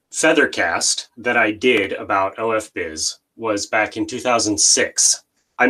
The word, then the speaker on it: OFBiz: So we pronounce it as OFBiz. – All right. – When David and Andrew Zanetsky give the name, so it is open for business. It's OFBiz. So we pronounce it like this.